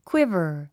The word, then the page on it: quiver: kwívə r; クイヴァ